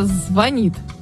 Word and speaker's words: звонит: Мне не нравится, когда неправильно произносят слово «звонит» с ударением на «о».